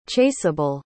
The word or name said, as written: chaseable